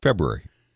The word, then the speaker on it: February: February. – February? – February. February. – February. – Yeah, February. – Huh. Okay. – Do you leave the R out of that right after the B?